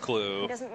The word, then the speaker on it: Clue: It's got GH at the end. Like, come on, guys. – You would never pronounce it that way unless you knew it was pronounced Clue.